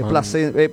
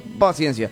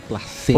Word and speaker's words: Plasencia: Así se escribe con ese, Plasencia. No es paciencia, es Juan Carlos Plasencia. Plasencia.